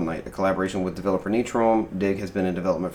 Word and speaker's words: Nitrome: Nitrome.